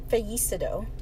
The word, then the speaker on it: fallecido: But yeah, they wrote fallecido. Am I closer? – It was much closer.